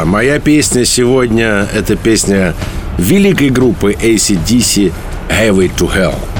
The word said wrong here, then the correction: Highway to Hell: Highway to Hell.